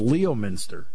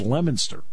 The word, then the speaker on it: Leominster: There's a town north of Worcester that is spelled like it's Leominster. Leominster. It's Leominster. It's like, okay.